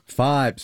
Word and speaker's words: Fybe: Am I saying this right? Fiber? – Fibe. – Sorry. – See, I believe. Yeah. Yes. F-Y-B-E. – F-Y-B-E. Fibe.